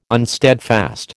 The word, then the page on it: unsteadfast: American English